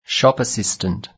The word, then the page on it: shop assistant: British English